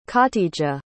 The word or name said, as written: khatijah